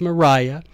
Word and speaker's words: Maria: But this is the catechism I'm sharing with you today, written in 1830 by one Mariah Pinkney. It's spelled Maria, but it's pronounced Mariah.